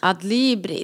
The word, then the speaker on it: Adlibris: på jag kan inte uttala det, men Adlibris? Adlibris. Adlibris, ja. så heter det ja.